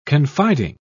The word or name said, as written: confiding